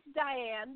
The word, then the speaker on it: Dianne: It's Diane, but that's okay.